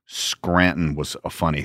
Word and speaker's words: Scranton: You don't pronounce the T. – Right. Scranton.